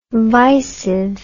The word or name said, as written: visive